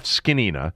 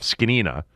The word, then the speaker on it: Zgonina: But he was asked just about the energy that the new defensive line coach, Jeff Skinnina, and that is how it's pronounced, I've been told, by people out in Ashburn. Coach Z is how he's known by his players. It's spelled Z-G-O-N-I-N-A, but I've been told to pronounce it Jeff Skinnina.